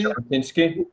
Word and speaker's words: Jabotinsky: Do I pronounce his name correctly? – Jabotinsky.